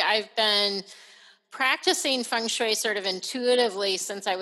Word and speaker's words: feng shui: And when it comes to it, I'm thinking, I don't even know if I say feng shui, if I'm saying it right.